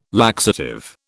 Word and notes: laxative: British English

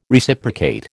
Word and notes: Reciprocate: American English

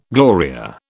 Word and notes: Gloria: British English

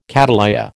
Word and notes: cattleya: American English

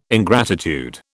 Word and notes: ingratitude: British English

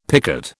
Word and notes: picot: British English

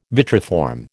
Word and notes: vitriform: American English

